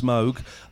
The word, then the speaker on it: Moog: Moog instead of Moog synthesizer, it's pronounced Moog.